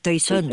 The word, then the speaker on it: Toisón de Oro: No sé cómo se pronuncia bien. – Toisón de Oro.